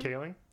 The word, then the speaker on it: Kaling: Oh, with Mindy Cullen. Is that how you say her name? – Kaling? – Yes. – Yeah.